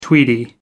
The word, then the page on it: tweedy: American English